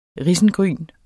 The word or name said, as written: risengryn